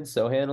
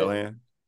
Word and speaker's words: Sochan: me of Jeremy Sochan a little bit. – Sohan.